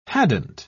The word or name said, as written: hadn't